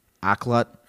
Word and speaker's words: Akhlut: It's spelled A-K-H-L-U-T and pronounced like Akhlut or, yeah, Akhlut, Akhlut.